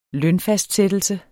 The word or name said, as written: NETTO